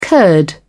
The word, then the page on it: curd: British English